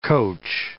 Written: coach

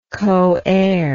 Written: coheir

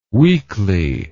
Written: weekley

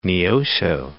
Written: Neosho

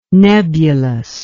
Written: nebulose

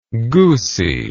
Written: goosy

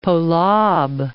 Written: Polab

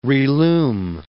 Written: relume

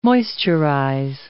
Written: moisturize

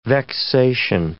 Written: vexation

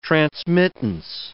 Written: transmittance

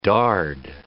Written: Dard

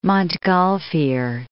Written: Montgolfier

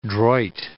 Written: droit

